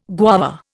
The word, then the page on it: guava: Indian